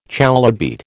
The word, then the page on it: chalybeate: American English